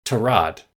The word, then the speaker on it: Tyrod: And finally, it came out because he actually mentioned it to some of his teammates in the Browns that he, pronounces his name and his family pronounces his name Tyrod.